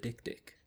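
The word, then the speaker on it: dik-dik: It is called the Dick-Dick. – The Dick-Dick? – Yep. – The Dick-Dick. How do you spell it? D-I-K? D-I-K.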